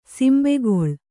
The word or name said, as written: ಸಿಂಬೆಗೊಳ್